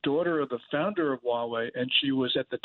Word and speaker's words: Huawei: And what about the, what is it, Mawa? Am I pronouncing it correct? The phone company? – Huawei.